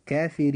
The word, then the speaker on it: كافرين: كلمة كافرين حرف الراء في هذه الكلمة جاء مكسورا، وإذا جاء حرف الراء مكسورا في أي كلمة فوجب ترقيق هذا الحرف فتنطق كلمة كافرين هكذا كافرين.